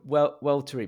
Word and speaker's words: Weltory: So it's it's called Weltery, but it's spelt Weltory, which is an indication of the principles maybe behind it.